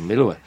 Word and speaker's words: Melua: Jeg, ja, kommer ind fra Herning og lærer en vestjyde, hvordan man skal sige Melua. Ja. Man skal sige Melua.